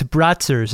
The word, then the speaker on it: Brazzers: This was Brazzers? – Come on, they're not in Manhattan. – Oh, I pronounced that wrong. It's Bratzers, isn't it? – Oh, yeah, that's more correct, technically.